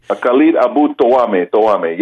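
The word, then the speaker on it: Khaled Abu Toameh: Well, one of the things that stood out was the journalist, the Palestinian journalist, Khalid Abu Tawameh, if I'm pronouncing his name correctly. – Khalid Abu Tawameh, yeah, from Jerusalem Post, from the J-Post.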